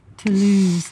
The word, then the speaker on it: Toulouse: So, any of those of you who are tuning in right now, it's what, like 6:30 in the morning here in Toulouse? Am I saying that correct? – Pretty close. I don't even understand how they say it. It's like Toulouse. – Toulouse, like an accent at the end.